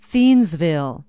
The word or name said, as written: thiensville